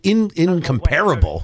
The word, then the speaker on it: Incomparable: That's the name of it, the Incomparable Network, right? – I think they pronounce it incomparable. – Incomparable. – Incomparable. See, you got to get UK style.